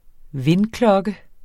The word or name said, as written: vindklokke